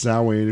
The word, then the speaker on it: Samhain: There was a character called Samhain, which we know is pronounced Samhain if you're pagan.